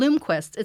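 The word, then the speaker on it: Blomkvist: Bloomquist. That's how you pronounce that. Mm-hmm. Bloomquist.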